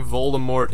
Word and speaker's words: Voldemort: According to J.K. Rowling, Voldemort is pronounced Voldemort.